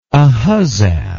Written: Ahuzzath

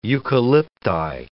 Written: eucalypti

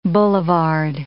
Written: boulevard

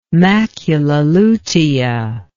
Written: macula lutea

